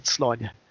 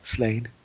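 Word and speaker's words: Sláine: I have friends over here, and they pronounce it Slania. So there you go. Mm. I don't know how Pat pronounces it. Pat Mills, I think who created the character, I think he pronounces it Slain. And that's fine. You know, it's his character.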